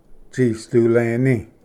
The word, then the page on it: ᏥᏍᏚᎴᏂ: tsisduleni